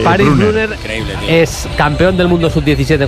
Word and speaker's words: Brunner: Brunner Alemania. – Ahí no tiene problema el Sala en pronunciar, Bruno.